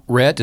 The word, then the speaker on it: Rhett: Rhett is R-H-E-T-T. Laubach is L-A-U-B-A-C-H.